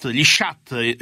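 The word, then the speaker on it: sciatt: Gli shut in un minuto, come si dice in valtellinese? – Shut, è giusto, Pier, è giusto.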